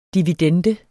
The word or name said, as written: dividende